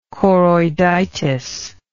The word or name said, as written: choroiditis